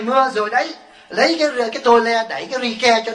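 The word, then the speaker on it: tôle: Cái tôi nói tua le là ổng nè, tua le là cái tấm tôn đó, tại vì hồi xưa cái chữ tôn là chữ tole thì nó đọc là cái tấm tua le đẩy cho có ri ke, ri ke là rice đó, cái bao gạo là rice.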